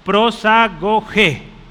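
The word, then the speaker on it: prosagoge (προσαγωγή): Sí, si usted pronuncia esas letras significa o se dice, perdón, prosagoge. El acento está en la e.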